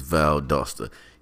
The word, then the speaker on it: Valdosta: Valdosta, that is the proper pronunciation for those of y'all who don't know, is Valdosta.